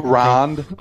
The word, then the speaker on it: Rand: comparing and contrasting Emma Goldman and Ayn Rand. Or is fucking strike. What the hell does strike? – Rond. – Rond. Mr. pronounces everything wrong.